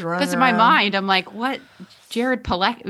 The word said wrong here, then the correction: Padalecki: you know, Pilecki or whatever his name is. Padalecki, sorry. I don't want to mispronounce his name.